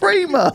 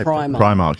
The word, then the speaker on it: Primark: Primark. It's not P-R-E-E, is it? It's P-R-I. – Primark, yeah. I think it is. I mean, officially, I think it is pronounced Primark.